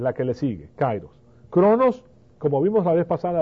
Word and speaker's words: chronos: La primera es cronos y se escribe C-H-R-O-N-O-S, cronos, cronómetro, de ahí viene la palabra cronómetro.